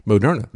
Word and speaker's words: Moderna: As a matter of fact, they pronounced their company's name at the time as Mode RNA. And then they changed it. They put the emphasis on a different syllable, and they called it Moderna.